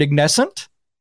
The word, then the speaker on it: Ignescent: Jennifer is the lead singer for the band Ignescent.